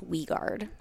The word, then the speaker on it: Wiegard: It's Dutch-German. It's Wiegard.